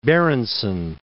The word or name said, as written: Berenson